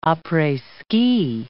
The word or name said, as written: apres-ski